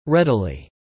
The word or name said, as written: readily